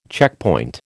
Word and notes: checkpoint: American English

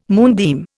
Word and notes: moonbeam: Indian

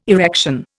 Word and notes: erection: Indian

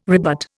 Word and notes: rebut: Indian

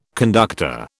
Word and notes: conductor: British English

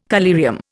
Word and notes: collyrium: Indian

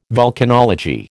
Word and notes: vulcanology: American English

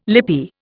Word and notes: lippy: Indian

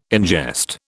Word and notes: ingest: British English

